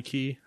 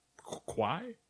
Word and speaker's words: Qi: I think you're supposed to pronounce it key, maybe. Probably key. That's how. I mean, that's how I would. I would assume it's key and not qui.